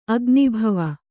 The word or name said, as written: ಅಗ್ನಿಭವ